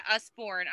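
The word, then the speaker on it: Usborne: Usborn? – Yeah, Usborn.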